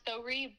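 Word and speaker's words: Thawrih: Um, it's pronounced Thori, but if you think about it and you want to see how you say it, sorry with a lisp, Thori.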